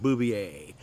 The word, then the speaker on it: Bouvier: We pronounce it Bovier, spelled the same as Jacqueline Kennedy's name was. She pronounced it Bouvier.